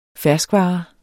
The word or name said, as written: fersk